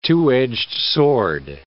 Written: two-edged sword

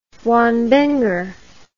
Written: wambenger

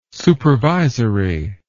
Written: supervisory

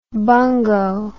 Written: bungo

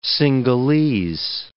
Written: Sinhalese

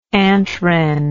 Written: antwren

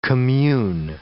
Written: commune

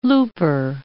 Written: looper

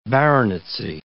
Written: baronetcy

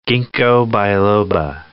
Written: ginkgo biloba

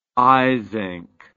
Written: eysenck

